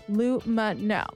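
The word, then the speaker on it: Lumineux: So there's a reason why they pronounce their name Lumino.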